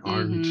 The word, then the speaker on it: Arndt: We used to call it like a cough. It was like Arndt, you know.